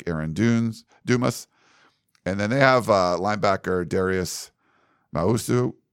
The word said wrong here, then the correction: Muasau: Is that how you say it? – Muasau.